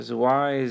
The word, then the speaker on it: Zwei: So I saw Zwei, Z-W-E-I, I'm not sure if I'm pronouncing it right. – Yeah, it sounds right.